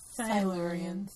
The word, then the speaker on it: Silurians: Oh, yes, next week we're talking about episode 8 and 9, and we go underground to meet the Silurians. – Woohoo! – Did I say that right? – Silurians.